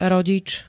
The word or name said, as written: rodič